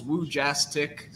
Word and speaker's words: Wojcik: You know, it's just three syllables, Wojcik. So, it's Polish. So definitely a little different than your typical last name, but, once you kind of get the hang of the syllables, it's pretty, pretty easy to pronounce.